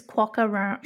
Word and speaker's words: quokka: Well, it's hard to believe that nobody thought of this before because quokka rhymes with docker.